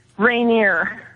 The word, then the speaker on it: Rainier: How do you pronounce it? – Rainier. – Rainier. – Like the mountain. – Like Mount Rainier.